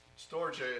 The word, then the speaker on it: storge: Storge, it's pronounced, is what I understand. I'm no Greek scholar, but that's what I was told.